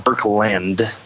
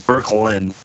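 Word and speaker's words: Boecklin: It's German, but it's not Berklind. Yeah, you threw a D on there, like Berklind. It's Berklind.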